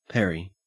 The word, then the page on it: peri: Australian English